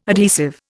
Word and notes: adhesive: Indian

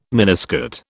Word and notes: miniskirt: British English

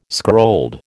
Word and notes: scrolled: American English

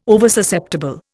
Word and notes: Oversusceptible: Indian

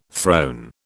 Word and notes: throne: British English

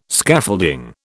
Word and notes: Scaffolding: British English

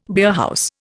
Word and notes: beerhouse: Indian

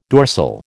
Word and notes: dorsal: American English

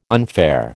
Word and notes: unfair: American English

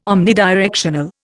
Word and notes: omnidirectional: Indian

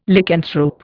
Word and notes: Lycanthrope: Indian

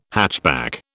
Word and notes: hatchback: British English

